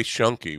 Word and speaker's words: Schoenke: Ray Schoenke was among the earliest Polynesians.